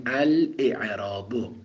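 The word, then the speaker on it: الإعراب: همزه اعراب همزه قطعه، چون باب افعاله، لذا تلفظ میشه، میگیم الاعراب. اما همزه اسم همزه وصله، باید بیفته، میگیم فلسم المعرب.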